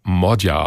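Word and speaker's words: Magyar: Er schreibt sich zwar Peter Magia, also mit Y, spricht sich aber Modja aus.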